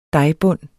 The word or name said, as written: dejbund